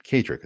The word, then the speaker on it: Kaedrich: Just look for my name, Kaedrich. It's spelled K-A-E-D-R-I-C-H, and you'll unlock a whole world around you.